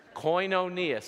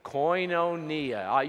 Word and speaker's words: koinonia: Koinonia. Say it with me. Koinonia. You can say it better than that. Say it like you know Greek, right? Koinonia.